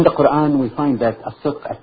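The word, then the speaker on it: as-sidq (الصدق): Well, your pronunciation was close enough. It's as-sidq, yeah.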